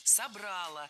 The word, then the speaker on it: собрало: В отличие от язычества, от того, что было до христианства, именно христианство собрало наш народ воедино. – Собрало, неверно. А правильно, как вы, наверное, уже догадались, собрало. Опять же, убираем ударение с окончания, однозначно. Только собрало и никак иначе.